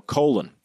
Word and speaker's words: Colin: he demanded people call him Colin. Even though his name is spelled exactly the same as the name Colin, right. C-O-L-I-N, he stuck with his Jamaican parents' pronunciation of his name,